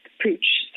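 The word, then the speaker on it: Puig: So my current role with Pooch, so it's P-U-I-G, most people don't know how to pronounce it.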